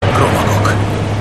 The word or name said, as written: kromaggok